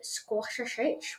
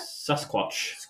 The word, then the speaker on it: Sasquatch: And I've got a squashashich, whatever it's called. Can you pronounce that? – Sasquatch.